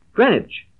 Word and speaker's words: Greenwich: Greenwich. That's the name of the place. It's spelled as if it were pronounced Greenwich, but it's called Greenwich.